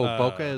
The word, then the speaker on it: bokeh: Oh, bokeh is a photography effect where things are blurred.